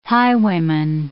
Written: highwayman

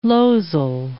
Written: losel

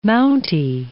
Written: Mountie

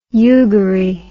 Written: yugarie

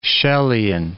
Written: Chellian

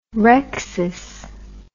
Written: rrhexis

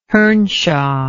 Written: hernshaw

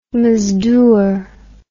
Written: mazdoor

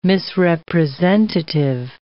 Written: misrepresentative

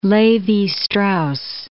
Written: Levi-Strauss

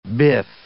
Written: biff

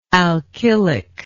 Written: alkylic

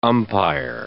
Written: umpire

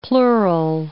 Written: plural

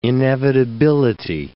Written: inevitability

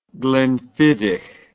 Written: glenfiddich

